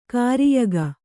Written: ಗೋರಿಯಿಕ್ಕು

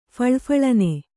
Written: ಫಳಫಳನೆ

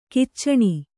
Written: ಖಚನ

